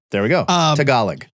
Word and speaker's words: Tagalog: It's pronounced Tagalog. Tagalog, our Girl Scout cookies.